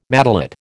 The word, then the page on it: matelot: American English